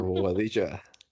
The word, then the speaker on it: Bobadilla: I'm Katie Gordon with my co host Leonardo Bobadilla with rhymes with quesadilla.